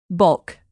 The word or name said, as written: bulk